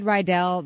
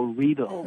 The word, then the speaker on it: Riedel: Because I said Riedel like an American. How is the proper pronunciation? – Well, it rhymes with needle, so Riedel.